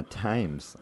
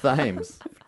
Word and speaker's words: Thames: I'm going to go drown myself in the Thames. I fucked up saying it wrong. – That's what I'm... Oh. – Thames. God damn it. – You got it half right.